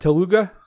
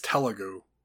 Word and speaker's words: Telugu: How do you pronounce that? Telugu? – I think it's Telugu.